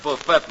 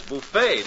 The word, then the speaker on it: buffet: About the food, Carpenter, I think I'll just serve a slight lunch, buffet style. – No, not buffet, lady. The word is buffet. The tea is silent.